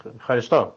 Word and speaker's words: Ευχαριστώ: A ver si lo digo bien, Ευχαριστώ. – Ευχαριστώ. Muy bien, sí.